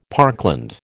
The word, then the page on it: parkland: American English